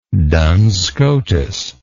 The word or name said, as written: Duns Scotus